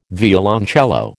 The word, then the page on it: violoncello: American English